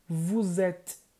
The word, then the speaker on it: vous êtes: Seria vous êtes. Vous êtes. Essa é a pronúncia.